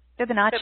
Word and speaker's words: Fibonacci: Um, I'm looking at the how do you say that word? – Fibonacci, Fibonacci, right? – Okay.